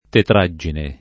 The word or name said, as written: tetraggine